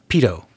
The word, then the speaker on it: Peto: I mean, it's spelled P-E-T-O, but you pronounce it and it sounds just like in the IT crowd, right? – Yeah.